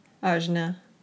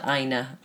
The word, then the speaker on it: Ajna: I always have problems with the J. Ajna. Okay, so it's not Aina.